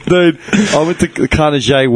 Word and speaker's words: Carnegie: The Carnegie. I heard someone pronounce it like that once. – Are you serious? – All real estate agents pronounce it that way. – Dude. – Carnegie. – Swear to God. Because it classes it up.